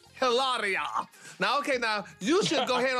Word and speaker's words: Hilaria: Because I remember when I met her, like, she corrected me on how to say her name. It took me so long to pronounce Hilaria.